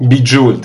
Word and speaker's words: Bejeweled: C'est pas des inconnus puisque c'est les gens qui sont derrière Bejeweled. J'arrive jamais à le prononcer correctement. – Bejeweled. – Bejeweled, le 1 et le 2.